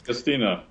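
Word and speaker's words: D'Agostino: counterpoint discussion that you had with Abby Smith, Ryan, Lane Norton, and I can never pronounce the guy's name, but it's Agostini. Is that right? – Dom Agostino. – Yeah, okay. I was close enough.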